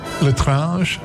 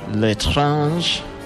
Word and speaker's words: L'étrange: was Horrific Child from, how do you pronounce this? L apostrophe E-T-R-A-N-G-E. – L'étrange. – L'étrange. That's good, man.